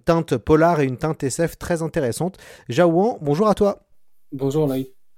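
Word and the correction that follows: Jaouen: c'est Jawen, mais tout le monde m'a écorché mon prénom toute ma vie, donc euh, j'aurais dû te le dire avant. – Et eh bah ben, ce sera Jawen à partir de ce, à partir de ce moment-là, voilà.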